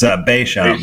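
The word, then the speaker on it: Béchamp: Is it B-E-C-H-A-M-P? Is it called, pronounced Beauchamp? – It's Beauchamp. It's Beauchamp.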